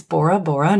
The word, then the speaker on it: Bora Bora: then this one rhymes with Nora. – Bora Bora? – Yes.